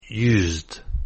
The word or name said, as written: used